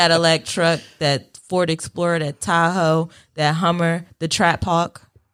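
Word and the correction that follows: Trackhawk: Am I saying it right this time? – No. – Track? – No, yes. Track Hawk.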